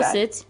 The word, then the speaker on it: Fawcett: Please do Fawcett.